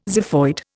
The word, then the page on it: xiphoid: Indian